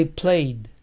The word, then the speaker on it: played: I played. Yo jugué un juego. I played a game. I played a game. La D suena como R, pues está entre vocales.